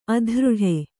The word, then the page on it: ಅದರಟ್ಟು: adaraṭṭu